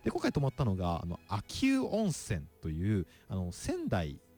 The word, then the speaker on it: 秋保: で、これ漢字がずっと読み間違えてたんですけど、これ秋保温泉と書いて秋保温泉なんですね。保ってのは保つという漢字なんです。これうと読むようで、これ秋保温泉というところなんですけど、泊まりに普通に行きまして、綺麗なんですよね。